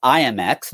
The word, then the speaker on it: IM=X: And it's pronounced IMX, not I M equals X. So we just say IMX.